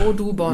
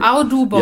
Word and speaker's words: Audubon: A-U-D, ja. A-U-D und dann U und dann B-O-N, hätte ich gesagt. Audubon. – Wie man spricht. – Entschuldigung. Audubon. – Ja, genau. – Audubon.